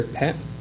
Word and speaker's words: PET: And to his left, man who started at Commodore, designed titles for the, it's pronounced P-E-T or PET? – Yeah, PET.